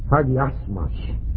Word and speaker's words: hagiasmos: I didn't pronounce it exactly right. It's hagiasmos. The emphasis is on a different syllable. It's spelled H-A-G-I-A-S-M-O-S. I left the A out and the emphasis. Hagiasmos.